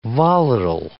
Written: volatile

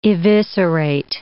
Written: eviscerate

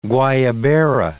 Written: guayabera

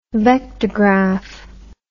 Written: vectograph

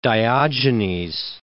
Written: Diogenes

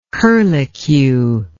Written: curlicue

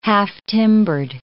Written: half-timber

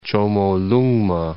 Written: Chomolungma

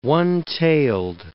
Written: one-tailed

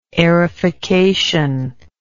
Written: aerification

